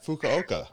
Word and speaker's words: Fukuoka: Fukuoka.